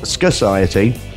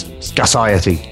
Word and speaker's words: Scarciety: They are pronounced scarciety, not scarciety. I've checked that out. – Ah, okay. – It's scarciety. It's like society, Tony, only it's scar.